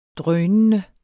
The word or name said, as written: drønende